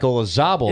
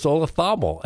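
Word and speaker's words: Olazábal: I'm still obsessed with Olazabal. In what language does a Z get pronounced? – Spanish. – I don't care for it. I've never heard any other name where you don't pronounce a Z.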